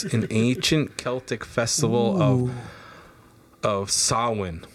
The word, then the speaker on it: Samhain: it's spelled S-A-M-H-A-I-N, but the pronunciation is Samhain. – Neat. – Very cool. – I just love thanks, Sam Hine.